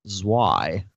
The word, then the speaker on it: Zwei: Zwei.